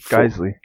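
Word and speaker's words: Geissele: I said Giselle? Isn't that kind of how you pronounce it? No, it's Geisley. – You're right. I don't know. – You're right. It is Geisley.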